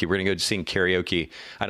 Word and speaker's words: karaoke: So everybody says karaoke. Do you have any idea how you actually pronounce the word karaoke? – Karate-oke. – No, not karaoke. It was very, very close, but not quite. No, karaoke is actually pronounced karaoke.